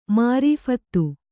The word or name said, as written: ಮಾರೀಫತ